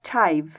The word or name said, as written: chive